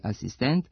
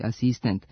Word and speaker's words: asistent: Zbog toga se oblici sa kratkosilaznim akcentima na posljednjem slogu, dirigent i asistent, akcentuju kao dirigent i asistent sa kratkouzlaznim akcentima na pretposlednjem slogu.